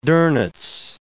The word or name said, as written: Donitz